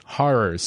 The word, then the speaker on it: horrors: The only reason it really stuck out to me is I was in a play once where I had to say the word horrors, and it kept sounding like whores, and so my director told me to say it like Snow did, horrors.